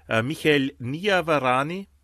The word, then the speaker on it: Michael Niavarani: Ja, steht ihm Michael Niavarani. So spricht man aus.